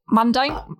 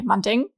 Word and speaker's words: Mandeng: Maximilian Kette, gespielt von Jonathan Elias Weiske, Anja Ernst, auch Anja Ernst, auch wieder goldiger Name, also wirklich, von Annabelle Mandeng, nee, Mandeng, sorry,